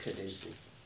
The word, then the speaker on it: caduceus: I think that, I don't remember how you pronounce it, I think it's Caducus. But Caduceus.